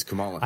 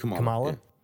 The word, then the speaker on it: Kamala: He's Kamala. – I know who she is. What's that? Kamala? – Yeah.